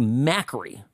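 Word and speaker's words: Makary: We have, and I was corrected here, it's Marty McCary. That's the proper pronunciation of this physician's Johns Hopkins University testifying to the Select Subcommittee on the Coronavirus Pandemic.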